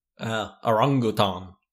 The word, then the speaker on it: orangutan: I've been so wrong about how you pronounce that animal's name my whole life. – If it makes you feel better, I think 99% of people pronounce it incorrectly. I just have primatologist friends. – Orangutan. That does sound a lot more like African.